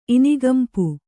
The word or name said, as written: ಇನಿಗಂಪು